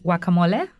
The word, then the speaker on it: guacamole: Okay, see, everybody else, like me, guacamole, you call it, you don't pronounce the G.